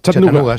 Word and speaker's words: Chattanooga: Chattanooga.